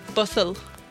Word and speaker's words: Butthole: Butthole.